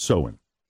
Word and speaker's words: Samhain: Samhain. That's how it's pronounced. Samhain.